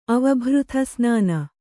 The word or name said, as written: ಅವಭೃಥಸ್ನಾನ